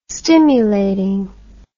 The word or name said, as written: stimulating